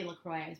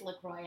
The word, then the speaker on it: LaCroix: No, it's supposed to be LaCroix. Yeah, it is LaCroix, and it like really offends a lot of people.